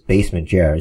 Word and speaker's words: Basement Jer: And then my name is on Twitter is Basement Jer. It's very easy. It's Basement Jerk without the K.